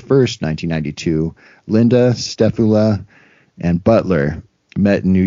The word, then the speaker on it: Stefula: And at the 1992 MUFON convention, Stefula, I doubt I'm pronouncing his name right, but Stefula. – Like a scapula. – Yeah, S-T-E-F-U-L-A, Stefula, sure, attended the convention and noted that some of the statements directly contradicted what Linda had earlier told Stefula and Butler.